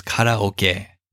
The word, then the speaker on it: karaoke: I don't mean to sound pretentious, but the proper pronunciation is karaoke.